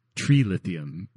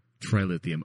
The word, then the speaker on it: trilithium: And he doesn't pronounce it like tree lithium because that to me just sounds like an idiot. – Well, so that tree lithium. Yeah. No, Commander, it's called trilithium.